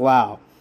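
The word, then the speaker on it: Lowe: It's spelled low, but it's pronounced yeah. Lau.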